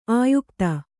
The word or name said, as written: ಆಯುಕ್ತ